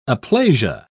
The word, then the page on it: aplasia: British English